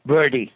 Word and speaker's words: Verde: Verde, yes. It's Verde is the word in Latin for green.